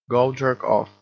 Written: go jerk off

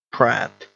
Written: prat

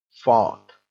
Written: fart

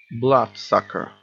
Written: blood sucker

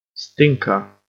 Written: stinker

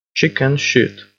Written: chicken shit

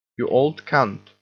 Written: you old cunt

